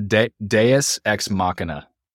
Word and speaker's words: Deus Ex Machina: How do you say that? – Yeah. It's a Deus Ex Machina.